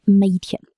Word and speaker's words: Mädchen: A umlaut sounds like a short E, as in bet. Think of Mädchen, meaning girl.